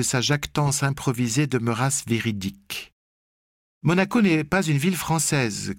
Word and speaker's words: Monaco: Récemment, je crois qu'ils sont allés dans votre pays, en France, la ville de Marocco, Manoco. Monaco ?» Elle me remercia, heureuse de rectifier son erreur, et répéta, afin de mémoriser la prononciation, « Monaco, Monaco, Monaco ».